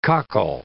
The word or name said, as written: cockle